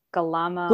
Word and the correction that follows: Gula Melaka: Gula Melaka.